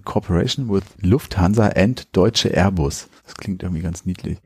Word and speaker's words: Airbus: Und zwar für A320 Airbus. – Das hast du voll schön gesagt. – Ja, danke. – Ich hätte es auf Deutsch ausgesprochen, aber daneben ist ja eine amerikanische Flagge.